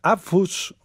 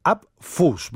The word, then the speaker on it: Apfus: Vamos ahora a escuchar Abfus, os lo deletreo porque es complicado de pronunciar, Abfus, ¿vale? A-P-F-U-S, volumen 1, que es el nuevo disco del músico y compositor argentino establecido en España, Julián Muro.